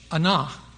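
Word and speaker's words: anah (ענה): The Hebrew word for afflict that we have been reading and afflict our souls, the Hebrew word is spelled A-N-A-H, anah. A-N-A-H, anah.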